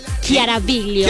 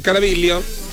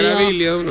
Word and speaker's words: Chiaraviglio: No, cuando es CH, si es que sí es italiano, el apellido se dice Ki. Chiaraviglio. – Chiaraviglio. – Chiaraviglio. – Chiaraviglio. – Chiaraviglio. – Biglio. – Biglio. – Biglio. – Biglio. – Biglio. – La G como que casi no se pronuncia.